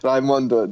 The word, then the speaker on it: Raimondo: Raimondo, that's great.